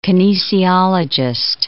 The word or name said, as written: kinesiologist